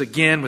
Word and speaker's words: again: He says again with again, a long A sound.